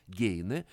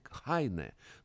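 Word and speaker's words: Heine: Незадолго своей гибели, меньше чем за год до гибели, Лермонтов неожиданно переводит стихотворение с немецкого, у известного немецкого романтика, поэта Гейне, ну, вообще-то он по-немецки произносится фамилия Хайне, но по-русски его принято называть Гейне, Генрих Гейне, известнейший немецкий романтик, один из крупнейших поэтов Германии.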